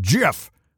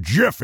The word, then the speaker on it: GIF: You gotta be high on paint fumes if you think Jif is how it's pronounced. Jif is peanut butter. Even Jif brand peanut butter tweeted that it's pronounced Jif. – Hold on, excuse me. Oxford Dictionary says it can be pronounced either way.